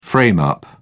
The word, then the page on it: frame up: British English